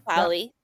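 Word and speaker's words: Polley: And how do you say it's Polly? Is that how you say it? Or Sarah Polly? – Sarah Polly, yeah. – Polly. Yeah. Okay, I wasn't sure like the inflection of her last name was, but that was just absolutely gorgeous.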